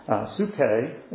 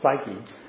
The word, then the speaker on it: psyche: The King James translators saw fit to translate that same word, psyche, psuche, if you want to pronounce it in the Greek, but we would pronounce it in English as psyche, 58 times as soul and 40 times as life.